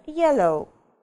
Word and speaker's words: yellow: Ну, например, yellow. Yellow, желтый, знаем мы все это слово, да? Вот смотрите, yellow. У нас слог, ударение падает на первый слог yellow. Поэтому вот это сочетание будет читаться безударно. Yellow, oh.